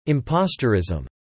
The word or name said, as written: impostorism